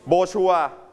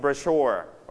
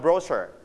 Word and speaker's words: brochure: ใครจำได้ว่าสิ่งนี้นะครับออกเสียงว่าไงในภาษาอังกฤษ brochure ไหมเราออกเสียงเน้นที่ you stress on first or second syllable. Second syllable. So you pronounce it brochure. Okay, brochure. But in British we pronounce brochure. Okay, you can pronounce it like Brochure or brochure, it's okay, okay?